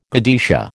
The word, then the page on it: godetia: American English